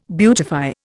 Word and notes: beautify: Indian

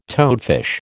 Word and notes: toadfish: American English

